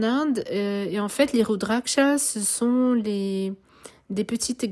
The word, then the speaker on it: Rudraksha: Rudraksha, c'est R-U-D-R-A-K-S-H-A. Mala, M-A-L-A.